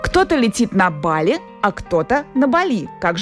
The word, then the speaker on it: Бали: Правильно, Бали. Филологи объясняют это особенностями языков, распространенных в Индонезии. Между тем, Юля выбрала авторское название для этого острова. Мне нравится ударение на «и», потому что это что-то такое экзотическое получается.